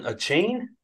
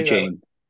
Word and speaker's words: Achane: So I'm going to go, is it Devin Achane? Achane? How do you say A-chane. – That? – Achane.